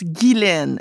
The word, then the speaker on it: Ghislaine: Oui, Ghislaine, parce que quand on regarde le nom Ghislaine, ça s'écrit G-H-I-S-L-A-I-N-E, et c'est un nom très ancien. Et dans les anciennes écritures, on sait qu'en français, il y avait beaucoup de lettres muettes. Et la lettre S devant le L était effectivement muette.